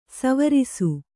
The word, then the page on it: ಸ್ವರಸ: svarasa